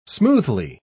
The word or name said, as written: smoothly